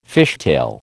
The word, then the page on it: fishtail: American English